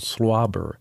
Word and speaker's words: SWLABR: And before Elvis Presley, we heard Cream with a really weird song called Slobber, which is spelled S-W-L-A-B-R.